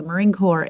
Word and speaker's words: Marine Corps: Marine Corps, the P is silent in Marine Corps. So it's the United States Marine Corps. It's a corps, which is a small elite unit, like you'll have a drum corps or a drum and bugle corps.